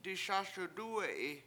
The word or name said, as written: diSAS1wdUwe7i